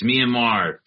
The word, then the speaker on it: Myanmar: Myanmar, is that how you say it? – Mm-hmm. – Yes. Nice. Myanmar.